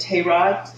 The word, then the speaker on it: Terah: I'm pronouncing it Terah, T-E-R-A-H,